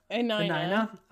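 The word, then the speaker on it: Enina: There is something interesting in that whole conversation too, where Mrs. Tandro, Alina, is that how you say it? Alina? Alina? – Anina.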